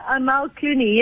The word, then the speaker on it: Amal Clooney: Is that how you say her name? – Amal Clooney, yes.